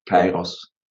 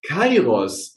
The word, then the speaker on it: Kairos: Und jetzt habe ich eben dreimal ein Wort gesagt, das ist, habe ich falsch ausgesprochen. Ich habe, glaube ich, Karios gesagt und es das heißt. Ja, Kairos. – Kairos.